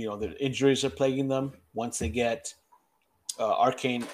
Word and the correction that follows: Achane: or uh, uh Akian back, whatever you fucking say his name, and Waddle. – Achane. – Achane, yeah.